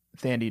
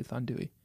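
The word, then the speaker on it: Thandiwe: It was narrated by Thandui Newton. – Okay. – OBE. – Mm-hmm. – Dame Thandui Newton, aka Thandi Newton, but she recently came out and said her name is actually Thandui.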